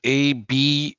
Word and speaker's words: A’bunadh: So it's spelled A-B-U-N-A-D-H, but the A-D-H, where you're from, Craigie, is actually pronounced A-R-H. So it's actually pronounced A’bunadh.